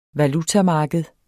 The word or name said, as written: valutamarked